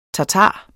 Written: tatar

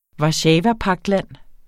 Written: warszawapagtland

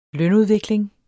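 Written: lønudvikling

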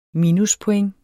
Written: minuspoint

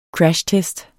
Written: crashtest

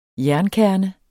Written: jernkerne

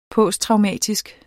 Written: posttraumatisk

